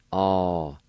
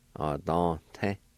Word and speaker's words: adante: Then in this word, the V with the N will be silent. You can pronounce the N sound with the D-A like this. A-don-te. A-don. Adante.